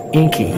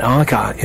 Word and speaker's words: Encke: That's E-N-C-K-E. And up until a few months ago, I was pronouncing it Inky, but my German friend, we saw the name on some kind of piece of gear on a sailboat with that same name, Encke, E-N-C-K-E. So she told me that that's how it's actually pronounced.